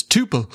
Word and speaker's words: tuple: So, and we want to know how you would pronounce the word spelt T-U-P-L-E.